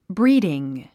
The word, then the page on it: breeding: bríːdiŋ; ブリィディン